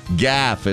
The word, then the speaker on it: golf: Gaff, as they say in the home country. G-O-L-F. The L is silent if you live in Scotland.